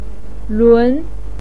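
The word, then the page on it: lun2: pinyin; tone 2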